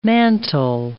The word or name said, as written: mantel